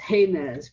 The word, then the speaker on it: Jemez: Or actually yesterday I learned, because I met someone from Jemez, that is pronounced Jemez principles,